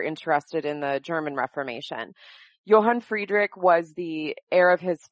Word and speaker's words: Johann Friedrich: Tell us a bit about Sibylla, and is it Johann Friedrich? – Yes, so in English, Sibylla and John Frederick, Johann Friedrich.